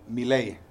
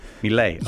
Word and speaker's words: Milei: How do you say Milei? – Javier Milei. Did I say it right? Milei.